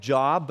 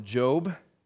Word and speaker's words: Job: It looks like Job, but you pronounce it Job.